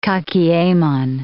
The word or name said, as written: kakiemon